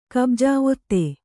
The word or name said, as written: ಕಬ್ಜಾ ಒತ್ತೆ